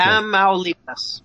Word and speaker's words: Tamaulipas: The state you mentioned, I always forget how to say it, Tamaulipas? – That's close. Okay. Tamaulipas.